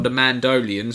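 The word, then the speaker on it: Mandalorian: It's going to be called The Mandolin, or The Mandolian, sorry, I should pronounce that correctly.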